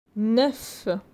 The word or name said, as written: 9